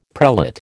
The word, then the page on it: prelate: American English